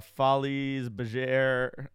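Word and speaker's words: Folies Bergère: We did two escape rooms, one Stranger Things themed and another one that was kind of a Follies Bergère, I don't know if I'm saying that word right, theater kind of thing. – You said it right.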